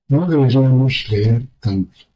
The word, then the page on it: leer: spaans